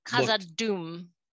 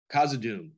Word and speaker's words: Khazad-dûm: And I just think the world building in particular in the first two episodes, Bazandur, no, how do you say it? The Dwarven Kingdom. – Khazad-dûm. – Khazad-dûm looked unreal.